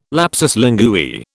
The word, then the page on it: lapsus linguae: British English